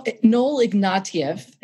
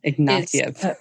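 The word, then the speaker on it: Ignatiev: Noel Ignatius, you talk about him. Why is he important? – Well, Noel Ignatieff. – Ignatieff.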